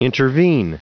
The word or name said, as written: intervene